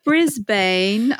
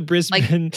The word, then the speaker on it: Brisbane: I think it's just, you almost try to say it in one syllable. – Brisbane. Like Frisbee, but.